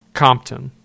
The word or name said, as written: Compton